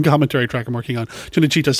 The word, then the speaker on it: Cinecittà: I always mispronounce it. Cinecittà? Does anybody know if that's right, the Italian? – I believe it's Cinecittà.